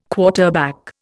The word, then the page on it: quarterback: Indian